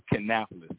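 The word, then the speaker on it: Kannapolis: Canapolis, yes, sir.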